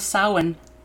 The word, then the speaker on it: Samhain: I know everyone, it, it, it looks like it's pronounced Samhain, but it's not. It's Gaelic.